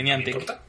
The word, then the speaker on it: Niantic: Niantic Games. Que no sé si lo estoy pronunciando bien. – Sí, Niantic.